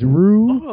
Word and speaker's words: Ryu: and he pronounced his name as Rue, and it was R-Y-U.